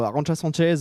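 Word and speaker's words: Arantxa Sánchez: Alors elle bat justement Arantxa Sanchez. Je le dis bien, hein.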